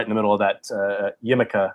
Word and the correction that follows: Yakima: I think I'm saying that right. Yakima, sorry.